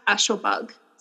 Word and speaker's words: Ashlebug: It's at Ashlebug. So it's spelled A-S-H-L-E-B-U-G.